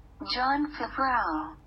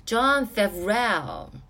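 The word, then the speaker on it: Jon Favreau: John Favreau。 – John Favreau，嗯，这个导演他导的一个真人动画电影叫做《狮子王》，我给他的打分是七分，挺失望的，嗯，挺不好看的，确实挺不好看的。这也是 John Favreau。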